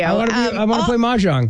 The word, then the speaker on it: mahjong: I think what I was kind of bringing it to was I've mentioned often on this show how, I don't know how to pronounce it. Mahjong? – Mahjong.